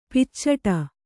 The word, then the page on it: ಪೀಚಾಡಿ: Kannada